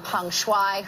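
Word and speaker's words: Peng Shuai: She says it how it's supposed to be pronounced. – Okay. – It's been nearly 3 weeks, and the mystery is only getting deeper around Peng Shui, her well-being.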